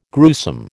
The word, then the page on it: gruesome: American English